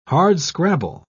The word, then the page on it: hardscrabble: British English